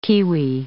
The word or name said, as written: kiwi